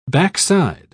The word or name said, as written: backside